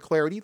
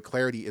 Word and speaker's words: Clardy: At Troy Clarity, the last name is spelled C-L-A-R-D-Y. At Troy Clarity is the way to go there.